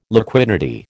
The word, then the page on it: liquidity: American English